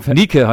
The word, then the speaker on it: Nike: Nike heißt das.